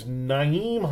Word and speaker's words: Nyheim: His name is Nyheim Hines, so kind of rhymes with 9.